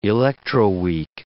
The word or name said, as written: electroweak